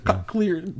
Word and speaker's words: cochlear: Cochlear. – Cochlear. Yeah. Watch your language, young man. The Koch brothers. – Well, I think it's like nuclear cochlear.